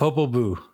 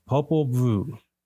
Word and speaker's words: Popol Vuh: The Popol Vuh. – Popol Vuh.